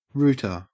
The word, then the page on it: rooter: Australian English